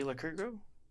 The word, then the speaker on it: Lycurgo: Jay Lycurgo? – That sounds very right. Yes. Yeah. Jay Lycurgo.